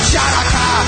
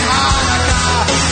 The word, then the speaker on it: Hanukkah: It's not pronounced Shanaka, the sea is silent in Hanukkah.